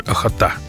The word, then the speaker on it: Охота: И вот там, в устье реки Охота, ударение на букве «А», как вы уже поняли, «Охота», в Хабаровском крае это, там рыбаки ведут лов кижуча.